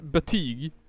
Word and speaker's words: Buttigieg: Jag tror att det uttalas betyg.